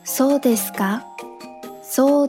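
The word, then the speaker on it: そうですか: 这个短语 so desu ka，so desu ka，出现在句子末尾的假名卡，它在此时的发音是嘎。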